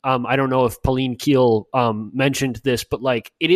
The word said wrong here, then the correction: Pauline Kael: Did I mispronounce her name, Aaron? You're narrowing your eyes at me. Okay, it's Kale.